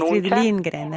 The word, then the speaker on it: Astrid Lindgren: Astrid Lindgren, autrice svedese, autrice di Pipi Calze Lunghe, molto conosciute.